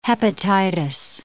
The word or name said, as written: hepatitis